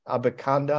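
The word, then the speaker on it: Abanikanda: Uh, Israel Abba Abakanda, I believe is how you pronounce the last name. – Yep. – Um, that's right.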